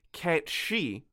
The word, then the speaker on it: Cait Sith: And I learned that the proper pronunciation is the Cat She, and I would like to mm.